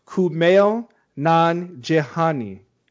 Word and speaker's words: Kumail Nanjiani: So even he doesn't know, but according to Google, it's pronounced Kumail Nanjiani. He himself advises fans to sound it out since his entire name is pronounced exactly like it's spelled.